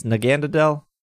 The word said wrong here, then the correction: Naganadel: Naganadel. – That's it. Naganadel.